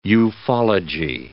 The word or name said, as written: ufology